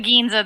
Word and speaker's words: Ginza: Like that scene when he's in the town of uh, uh, Giza or Giza, I forget how you pronounce it exactly. It's it's it's the Ginza. That's just the city portion of Tokyo.